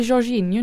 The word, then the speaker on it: Jorginho: Men jag har lärt mig Jorginho nu.